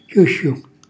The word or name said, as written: xjuxxju